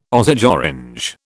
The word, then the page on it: Osage orange: British English